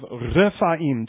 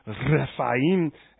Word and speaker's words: Rephaim: Because most of you know how to roll your R's, for instance. Rephaim.